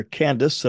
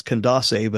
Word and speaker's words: Candace: So verse 27 says, "And behold, a man of Ethiopia, a eunuch of great authority under Candace," and I'm going to use the kind of the American pronunciation of that. I think Greekly it's Candace,